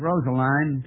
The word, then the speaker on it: Rosaline: They pronounced it Rosaline to rhyme with brine.